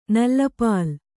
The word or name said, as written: ನಲ್ಲಪಾಲ್